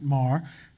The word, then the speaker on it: Meagher: But here is Colonel Marr, M-E-A-G-H-E-R, but I'm reliably told he pronounced it Marr, coming to the foot of Manhattan returning and getting a huge hero's brigade, a hero's welcome from women.